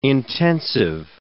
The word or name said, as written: intensive